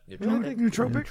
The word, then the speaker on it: nootropic: Yeah, nootropic. Mm. How do you pronounce that? – Nootropic.